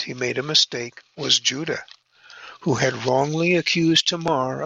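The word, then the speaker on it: Judah (יהודה): The Hebrew is Judah, pronounced like a Y, Judah.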